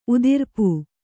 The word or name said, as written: ಉದಿರ್ಪು